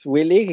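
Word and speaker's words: WE League: Sí, este es Willy, incluso los mismos japoneses la pronuncian así, como la Willy Ajá.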